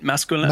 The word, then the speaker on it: masculin: Masculin.